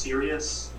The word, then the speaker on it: Sirius: Alright, uh, so, um, and your, your character's name is pronounced Sirius? – Yes, sir.